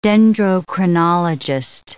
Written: dendrochronologist

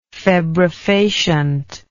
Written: febrifacient

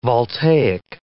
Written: voltaic

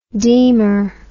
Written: deemer

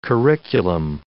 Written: curriculum

